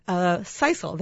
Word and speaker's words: sisal: You and I won't be looking at it right now, Pam, but it shows one of these longer sisal. That's how we pronounce the material? – That's how I pronounce it. – Okay, sisal, S-I-S-E-L material,